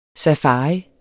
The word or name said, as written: safari